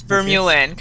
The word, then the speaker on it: Vermeulen: Yes, Vermeulen. Kind of starts like Vermont.